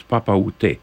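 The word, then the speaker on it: Papaoutai: The title is Papa Outai, so it's P-A-P-A-O-U-T-A-I, but if you pronounce it right, it says Papa Outé, where are you, Dad?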